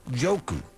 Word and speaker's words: Njoku: Out of the University of Miami, if I'm not mistaken, tight end David Njoku. The N is silent, if I'm not mistaken, right? Or they want me to say Njoku. I think it's just Njoku, right? – I think that's right. – N-J-O-K-U.